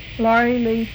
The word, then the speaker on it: Laurie Laigh: Laurie Lee. – Is that spelled with an I? – L-A-U-R-I-E. L-A-I-G-H.